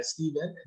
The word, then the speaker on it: Stephen: But you can find me there, Stephen. That's S-T-E-P-H-E-N.